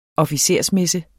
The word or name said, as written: officersmesse